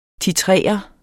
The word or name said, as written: titrere